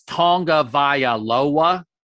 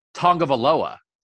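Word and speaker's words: Tagovailoa: One guy was saying Taulia Tongavaialoa with six syllables, and one guy was saying it with five. And it's Tongavaialoa. It's six. And the other guy, 97 times, said Tongavaloa. Tongavaloa.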